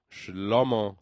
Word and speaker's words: Shlomo: Shlomo sería la pronunciación en hebreo, Shlomo.